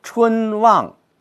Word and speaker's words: 春望: 比如说，我们读“ 春望”，我们不能把“ 春” 和“ 望” 都读成“ 重” 的，“ 春望” 不合适，应该是“ 中重” 格式。“ 春” 用中等的力量，“ 望” 用比较大的力度，而且“ 望” 又是一个仄声，应该是什么呀？“ 春望”，你看这就不一样了。